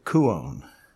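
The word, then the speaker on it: kuon (κύων): Here's the word, kuon, K-U, long O-N.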